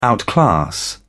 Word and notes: outclass: British English

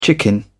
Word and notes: chicken out: British English

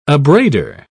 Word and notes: abrader: British English

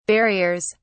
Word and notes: barriers: British English

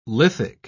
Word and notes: lithic: British English

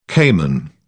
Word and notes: cayman: British English

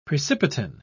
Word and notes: precipitin: British English

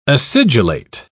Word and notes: acidulate: British English

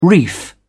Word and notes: reef: British English

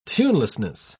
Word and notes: tunelessness: British English

